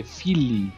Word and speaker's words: Philae: P-H-I-L-A-E, Philae, Philae.